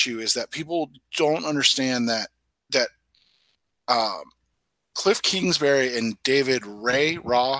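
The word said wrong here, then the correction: Raih: how do you say his name? – David Rye, the wide receiver coach, have literally been after Kyler Murray for what, 5 years now? – Yes, since he was in high school.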